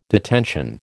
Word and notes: detention: American English

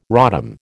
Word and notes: rodham: American English

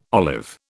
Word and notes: olive: British English

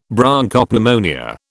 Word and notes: Bronchopneumonia: British English

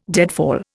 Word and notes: deadfall: Indian

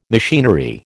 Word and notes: machinery: American English

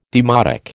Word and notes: demotic: American English

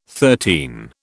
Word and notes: thirteen: British English

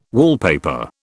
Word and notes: wallpaper: British English

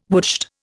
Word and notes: woodshed: Indian

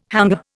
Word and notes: hangar: Indian

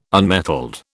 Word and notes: unmetalled: British English

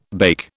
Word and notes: bake: British English

